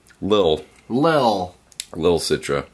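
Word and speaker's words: Lil Citra: This is Little Citra from Pipeworks in Chicago. – No, say it right. – 4.9%. – Not little. – Lil. Little Citra. – There you go.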